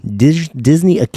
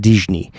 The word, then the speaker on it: Disney: Years later, after the silly symphonies ended, Disney, that's how you say it in Portuguese, Walt Disney.